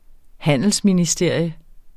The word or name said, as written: HANDELSMINISTERIUM